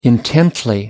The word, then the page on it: intently: British English